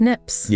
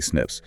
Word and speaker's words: SNPs: Pronounced SNPs. – Yeah, exactly, SNPs.